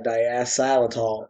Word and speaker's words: diacetyl: Like, you don't want that diacetyl. Am I saying that right? – I think so. That's close enough.